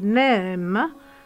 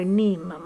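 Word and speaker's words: neem: Ha vinto delle battaglie naturalmente in questi anni di conflitto con le multinazionali, per esempio contro il brevetto del NEM, che si dovrebbe pronunciare NIM,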